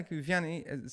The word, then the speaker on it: Vianney: Am I pronouncing that right? – Yeah, Vianney, Saint John Vianney, or in Maltese would say Saint John Vianney.